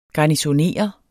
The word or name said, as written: garnisonere